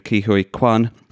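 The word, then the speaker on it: Ke Huy Quan: and the return to the big screen of Ki-Hui Kwan, who is wonderful as husband Waymond.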